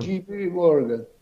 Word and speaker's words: JP Morgan: Bu Uluslararası Yatırım Bankası e, CHP Morgan nasıl okunuyor bilmiyorum. JP Morgan.